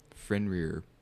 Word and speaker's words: Fenrir: Okay, I think that's Tyr, because, like, the section of the arm that's off the wall is the hand that Fenrir bites off.